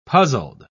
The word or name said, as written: puzzled